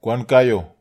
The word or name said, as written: kwankaio